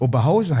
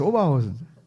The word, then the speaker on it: Oberhausen: Ja, das ist doch nicht Oberhausen, heißt Oberhausen.